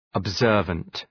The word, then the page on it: observant: əb’zɜ:rvənt